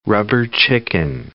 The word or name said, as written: rubber chicken